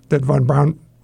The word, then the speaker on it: von Braun: And also, like in my case, the decisions that von Braun was making, did I pronounce that right? – You did.